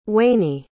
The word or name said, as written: waney